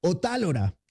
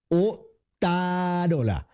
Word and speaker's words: Otárola: no Javier Otálora, como podría pensarse, sino así, Javier Otárola,